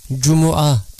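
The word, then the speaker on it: Cuma (جمعة): Dolayısıyla siz de bu kelimeyi mim harfinin ötreli okunuşunu kastederek Cuma diye okuyunuz.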